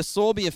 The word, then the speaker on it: Sauerbier: I have no control that I was born into the Sauerbier family and had to wear that name for the rest of my life.